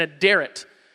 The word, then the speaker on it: aderet: an aderet. Aderet is how you pronounce it.